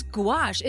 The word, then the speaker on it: gouache: Am I pronouncing that right? – I have no idea. How's gouache? – It spelled? – G-O-U-A-C-H-E. – Gauche? – Is that gauche? – Gouache? – Sounds like goulash.